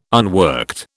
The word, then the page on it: unworked: British English